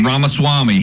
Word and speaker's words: Ramaswamy: But anyway, for Vivek, I'm trying to pronounce his name, Vivek Ramaswamy. – Ramaswamy.